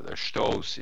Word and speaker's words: Stoos: So we started inviting people, and this turned out this became the Stews event. Stoos, it is actually pronounced in German,